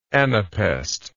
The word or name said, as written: anapaestically